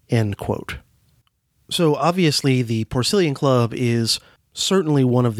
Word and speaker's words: Porcellian: Now, if you look at the name of the club, Porcellian, while it's spelled the same, the root pork, P-O-R-C, in the Porcellian Club, which is pronounced with a soft S in that case, is a very different word root from the P-O-R-C, pronounced with a hard C, in, say, Porkfest, which I attended back in the middle of the summer.